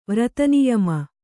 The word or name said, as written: ವ್ರತನಿಯಮ